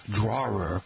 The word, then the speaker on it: drawer: It's not a person who draws, drawer, or drawer with a crayon, but most Americans pronounce it as a single syllable that ends in the R sound, like drawer or roar. But if you pronounce the other way as draw, which rhymes with straw, there's a good chance you're coming from New York City or maybe even from Taunton or New England.